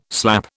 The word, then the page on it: slap: British English